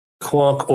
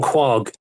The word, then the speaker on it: quark: Quark or quag, you can pronounce it.